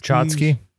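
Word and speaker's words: tchotchke: How do you say that when it's like junkie Schlotsky? – Chotsky. Chotsky.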